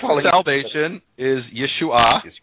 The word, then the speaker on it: Yeshua: And so, this is a noun that we have here, what's called a common noun, Yeshua, with the emphasis in the final syllable. It has a hey at the end, a silent hey. And then Yeshua is a name,